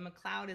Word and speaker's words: McCloud: And the McCloud is like a cloud. So not one of those McLeods.